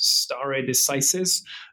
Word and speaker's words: stare decisis: Amerikanisch spricht man es aus Stare Decisis, ähm, das eigentlich heißt to stand by things decided sozusagen.